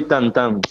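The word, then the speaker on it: tantã: Banjo y tantán, sí, tantán. – Bien. – Tantán, porque el nombre es tantán, porque el sonido... prácticamente es esto, tan tan tan claro.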